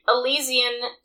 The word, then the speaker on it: Elysian: It's like Elysium, like the movie. – Elysian Space Dust IPA.